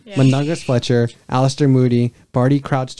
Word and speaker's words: Mundungus: Mundungus Fletcher, right? – What? – That's how you say it. – McDungus? – Mundungus.